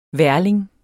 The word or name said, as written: værling